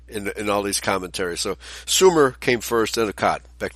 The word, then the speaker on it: Sumer: And yeah, that's pronounced Sumer.